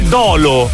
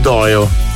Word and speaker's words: Dolo: E ricordatevi che Dolo è sbagliata come pronuncia. Quando vedete scritto il paese Dolo, non si legge Dolo. – Però dovrebbero fare come in... – Si scrive Dolo, ma si legge Doio.